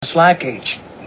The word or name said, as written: slide cage